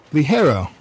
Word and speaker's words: Ligero: But yeah, it's pronounced Ligero.